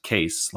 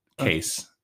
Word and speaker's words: Kees: And he spells his name K-E-E-S, and it's pronounced Case, like just Case.